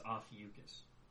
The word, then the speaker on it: Ophiuchus: I found a video that apparently the pronunciation is Ophiuchus.